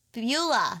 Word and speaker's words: Beulah: how do you pronounce her name? Is B-E-L something. Let me look this up. – Bellu. Bellu. – B-E-U-L-A. Beulah. – Beulah.